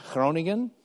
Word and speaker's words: Groningen: And then there's two schools there at the bottom you can see from, and this is actually how you say it, on the left is Groningen, and then on the right is Nieuwegein, both in the Netherlands.